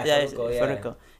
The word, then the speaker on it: Farruko: No, Farruko. I think. Is that how you say his name? – Yeah, Farruko.